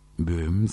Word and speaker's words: Böhm: Georg von Böhm, se dice, porque la O tiene un laut, perdón, tiene diéresis, se escribe B larga, O con diéresis, H, M. Georg Böhm escribió este hermosísimo coral sobre el himno del Padre Nuestro, Vater unser in Himmelreich.